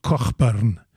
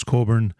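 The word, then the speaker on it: Cockburn: And even though it's spelled with a CK in the middle, which could be pronounced, you know, in Scottish brogue, Cochburn, but we call him Bruce Coburn, and he's a phenomenal, phenomenal guitarist.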